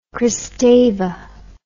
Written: Kristeva

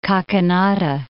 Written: Kakinada

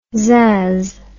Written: zazz

